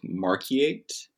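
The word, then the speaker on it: Markyate: Let's talk about St. Albans Psalter, which was owned by Christina of Marquiate. – Yes, that's right.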